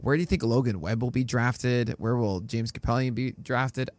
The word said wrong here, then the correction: Kaprielian: I keep pronouncing his name wrong. I think Kaprielian, whatever. It's Kaprielian. Kaprielian, wonderful.